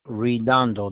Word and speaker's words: Redondo: And my last name is Redondo. That's R-E-D-O-N-D-O.